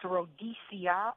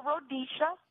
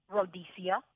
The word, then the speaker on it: Rodecia: It's grammatically, it's Rhodesia, R-O-D-E-C-I-A, or Rhodesia. – Uh-huh. – The pronunciation is correct. The C-I-A pronunciation.